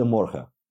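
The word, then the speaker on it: goedemorgen: Официально, правильно, оно произносится худе «худеморхе».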